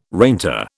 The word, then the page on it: reinter: British English